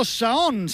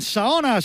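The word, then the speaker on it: Saône: Empieza la caravana ya a asomar por este bulevar fantástico, espléndido, que bordea el río Saón. Saona, si lo pronunciamos en castellano, es donde acaba hoy la duodécima etapa del Tour de Francia.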